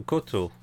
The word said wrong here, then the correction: Kohta: Ro. Koto. – Kota. – Kota. – So many extra syllables.